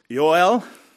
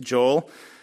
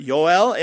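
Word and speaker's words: Yoel (יואל): This would be pronounced Yoel. We say Joel, but Yoel.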